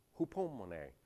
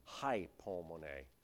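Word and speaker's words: Hupomone (ὑπομονή): Hupomone. You might see it written out as hypomone, because the English letter Y is really the pronounced as a U in Greek.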